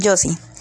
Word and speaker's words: Josie: Entonces, nuestra protagonista, que es Josie, bueno, Josie, perdón, este, como buen mexicano, trato de, de, leerlo, este, así como se, se escribe, pero es Josie.